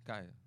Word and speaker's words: Khia: Kaya.